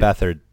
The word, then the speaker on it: Beathard: If you pronounce it phonetically, it is Beat Hard, okay.